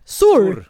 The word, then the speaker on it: Saur: Eh, då kommer Maria Saur. Sa jag rätt nu? Sur. Sur. Sur.